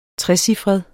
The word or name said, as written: trecifret